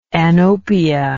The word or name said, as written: anopia